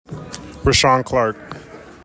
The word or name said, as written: RAHSHAWN CLARK